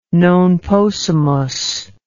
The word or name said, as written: non possumus